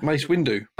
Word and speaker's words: Mace Windu: I think you said their name wrong again. – No. – Mace Windu. – Oh, Windu. Windu.